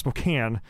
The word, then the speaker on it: Spokane: Like Spokane, just so you know, it's pronounced Spokane.